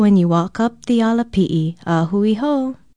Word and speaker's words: ala pi'i: Aloha, owau ole lani puli ahu from Hawaii Public Radio, and our Hawaiian word for today is ala pi'i. Ala pi'i is spelled A-L-A-P-I okina I, and it means stairs, steps, ladder, or any ascent.